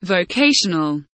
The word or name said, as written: vocational